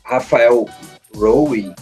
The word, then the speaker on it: Rafael Rowe: Uh, ela é uma série que o, tem um apresentador, o Rafael Rowe, que é assim que se pronuncia, ele é um cara que ele passou 12 anos preso na, em Londres por um crime que ele não cometeu.